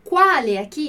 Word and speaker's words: quale: É muito importante a gente prestar atenção na pronúncia das palavras que terminam com e com i no final, porque a gente tem essa questão do plural e do singular, né? Em italiano. Então, quale, deixar bem explícito que eu estou falando com e, né?